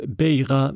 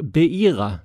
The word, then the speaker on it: Beira: En annan lyssnare har kommenterat mitt uttal av Beira Mosambik som inte är helt rätt. I ska uttalas som ett J, så istället för beira som jag sa så ska det alltså vara beira.